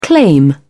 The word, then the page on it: claim: British English